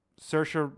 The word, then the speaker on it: Saoirse: Saoirse. – Saoirse Ronan.